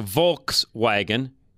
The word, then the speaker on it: Volkswagen: Volkswagen, which it's a Volkswagen, V-O-L-K, that's how you say it. We have, a lot of us in the United States of America have always said almost Volkswagen.